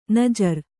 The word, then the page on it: ನಾಜರು: kannada